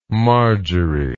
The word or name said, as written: margery